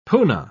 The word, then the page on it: puna: British English